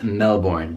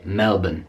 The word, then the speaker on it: Melbourne: Also in Australia is Melbourne, but I'm pronouncing that with my natural American accent. Maybe an Australian would say Melbourne.